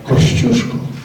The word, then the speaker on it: Kościuszko: As a Polish ambassador, I will take the liberty to pronounce Polish names in a Polish way. So Strzelecki is the equivalent Polish original way of pronouncing Strzelecki, while Kościuszko is how we pronounce Kościuszko.